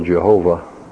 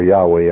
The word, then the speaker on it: Yahweh: And the fact that we have the title Jehovah Elohim, or Yahweh Elohim, depending on your preferred pronunciation.